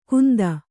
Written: ಕುಂದ

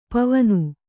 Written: ಪಾವನೆ

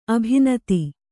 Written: ಅಭಿನೂತೆ

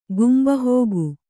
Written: ಗುಂಬಹೋಗು

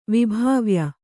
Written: ವಿಭಾವ್ಯ

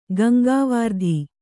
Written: ಗಂಗಾವಾರ್ಧಿ

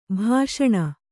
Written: ಭಾಷಣ

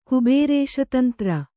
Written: ಕುಬೇರೇಶತಂತ್ರ

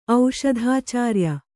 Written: ಔಷಧಾಚಾರ್ಯ

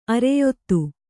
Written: ಅರೆಯೊತ್ತು

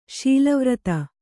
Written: ಶೀಲವ್ರತ

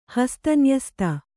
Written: ಹಸ್ತನ್ಯಸ್ತ

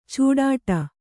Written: ಛಡ್ಡೆ

